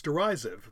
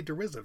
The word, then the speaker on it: derisive: I don't think this one, it's derisive, apparently, is pronounced. I always say derisive. That's a new law. I just learned.